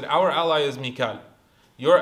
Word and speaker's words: Mika'il: And Mikael is one way that it's pronounced. It's also Mika'il, right? In a different qira'ah, you recite it as Mika'il.